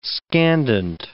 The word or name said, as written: scandent